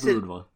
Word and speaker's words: boudoir: Like, he says boudoir. It phonetically. There's B-O-O-D-A-W. – Like Wario would say. – W-A-H.